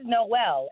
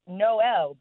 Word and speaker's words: MNOP: So, the name is Noelle, and that doesn't sound too crazy, except it was spelled M-N-O-P. – What? – Yes. So, when you look at it, it's spelled M-N-O-P, but it's pronounced Noelle because when you look at the alphabet, it's L-M-N-O-P.